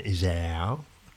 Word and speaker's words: Iseo: Uno in via Campo 72 a Iseo, bisogna pronunciarlo bene, Iseo,